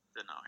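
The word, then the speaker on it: Danault: Denal. Um, I don't know if I pronounced that name right. Is it oh, Denal, or yeah, Denal? Denal, yeah.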